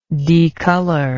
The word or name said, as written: decolor